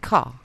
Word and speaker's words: Ka: A Ford car. – Apparently, it's supposed to be pronounced ca. – Oh, come on. – Because it's a British car.